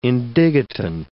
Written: indigotin

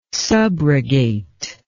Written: subrogate

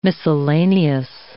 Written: miscellaneous

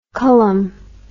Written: culham

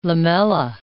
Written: lamella

